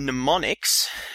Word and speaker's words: mnemonics: I want to briefly mention mnemonics. Now, if you want to Google this term and you haven't heard of it before, be wary that it starts with M. It's spelled M-N-E-M-O-N-I-C-S. Mnemonics.